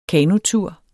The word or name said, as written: kanotur